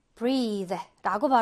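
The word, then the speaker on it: breathe: 자, 호흡하다, 숨쉬다는 breathe라고 발음해 주면 되고요. 명사로 breath, 요거랑 헷갈리지 마시고, 요거가 이제 호흡, 숨, 이렇게 명사로 해석이 되고, 발음도 breath라고 좀 다르게 발음이 될 수가 있습니다.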